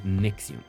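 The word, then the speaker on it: NXIVM: Pues todo el chismerío de esta asociación o como culto también que se, que se conoce como Nexium y que se escribe n x eh, i m o sea, es, raro, ¿no? Pero ah, se dice Nexium.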